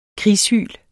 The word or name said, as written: krigshyl